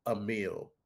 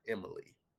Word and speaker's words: Emil: That Diesel E website that I mentioned, the website was correct because I spelled it, but it's pronounced Emil, not Emily.